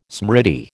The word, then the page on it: smriti: American English